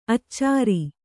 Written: ಅಚ್ಚರಿ

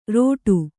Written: ರೋಟು

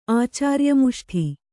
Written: ಆಚಾರ್ಯಮುಷ್ಠಿ

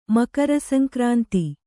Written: ಮಕರಸಂಕ್ರಾಂತಿ

